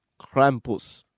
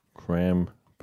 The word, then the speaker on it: Krampus: So the question that I have for you is, who is Krampus? – Krampus. – Spell that. Actually, can you get Google to say it, please? – Absolutely. – I like this new... I love technology. – Krampus.